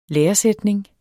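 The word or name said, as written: LÆRESÆTNINGEN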